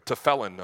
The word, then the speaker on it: tefillin: Here's where we get these boxes, the tefillin. Or tefillin, I'm sorry, I said it wrong, of the Hebrew faith.